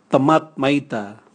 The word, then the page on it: tamwat maita: Raga